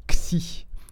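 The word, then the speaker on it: xi (ξ): Cette lettre, elle se prononce xi, se prononce comme le son d'un serpent, x.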